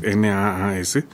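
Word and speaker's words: Nass: Lo expresa NAS, no sé cómo se pronuncia, es NAAS, por si alguien lo quiere consultar.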